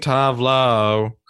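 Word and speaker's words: Tove Lo: Okay, I'm gonna pick No One Dies From Love by Tuvalu. Tuvalu. – Tuvalu. Tuvalu. – That's not how you say it. – You mean Tavlo.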